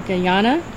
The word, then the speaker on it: Guyana: Guyana,